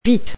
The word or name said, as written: vite